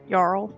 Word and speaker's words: Jarl: Jarl.